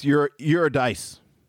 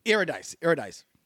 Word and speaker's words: Eurydice: And is it Eurydice? I never knew how to pronounce the woman's name. – I think it's Eurydice. – Eurydice. Mm-hmm.